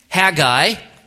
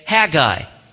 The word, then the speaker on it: Haggai: Now, as you turn there, know that there are three possible pronunciations for the book: Haggai, Haggai, and Haggai. And as you look at pronunciations and how people say it, those are the three options that we have. For the purpose of our study, I'm going to say Haggai.